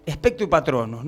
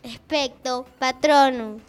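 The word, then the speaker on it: Expecto Patronum: Decime, a mí me gusta, por ejemplo, Spectro Patronus, ¿no? ¿Qué es eso? ¿Qué hechizo es? ¿Qué tipo de hechizo es? – Primero lo dijiste mal. – ¿Cómo se pronuncia? – Spectro Patronus.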